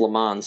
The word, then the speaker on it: Lemons: My last name is spelled Lemons, but it's pronounced Lamont.